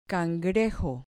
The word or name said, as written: cangrejo